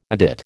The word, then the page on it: adit: American English